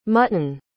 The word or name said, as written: mutten